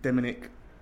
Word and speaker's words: Demenick: Paul War and Graham Demenick. Is that how you pronounce the name? – Yeah, Demenick, like Dominic, but with an E.